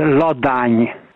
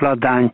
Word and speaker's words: Ladány: My name is really a Hungarian name. It is Ladány, like Püspök Ladány, but in English and in Hebrew it is pronounced as Ladány.